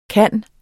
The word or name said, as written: kunne